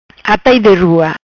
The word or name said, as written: Atai de rua